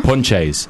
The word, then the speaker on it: Ponce: you'll meet as many Jesuses as you will Ponches. – Yeah. – Which, I mean, you meet a Ponche and you see how it's spelled, and it's just spelled Ponce.